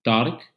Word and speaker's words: Taariq: It's Tarik, T-A-A-R-I-Q, or Tarik, like you say. That's how my name is pronounced in Arabic. T-A-A-R-I-Q @andrews.edu.